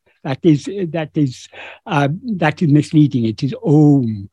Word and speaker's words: Om: So in other words, when we pronounce O, Om, we begin with our mouth open, allowing the air to pass out. Then we close the lips and finally come to M, which is the closed lip.